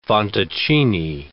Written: fantoccini

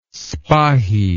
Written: spahi

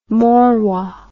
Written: mauroy

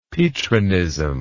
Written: petrinism

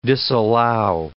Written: disallow

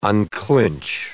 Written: unclinch

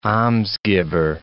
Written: almsgiver

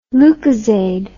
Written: Lucozade

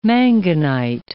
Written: manganite

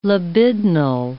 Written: libidinal

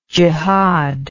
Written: jehad

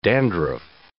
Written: dandruff

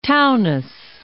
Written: Taunus

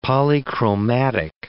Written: polychromatic

